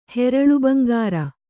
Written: ಹೆರಳುಬಂಗಾರ

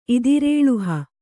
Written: ಇದಿರೇಳುಹ

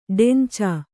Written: ಡೆಂಚಾ

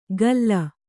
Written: ಕಲ್ಲ

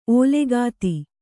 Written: ಓಲೆಗಾತಿ

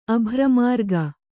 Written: ಅಭ್ರಮಾರ್ಗ

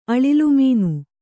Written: ಅಳಿಲುಮೀನು

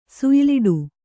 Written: ಸುಯ್ಲಾಡು

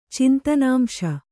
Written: ಚಿಂತನಾಂಶ

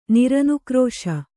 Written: ನಿರನುಕ್ರೋಶ

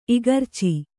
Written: ಇಗರ್ಚಿ

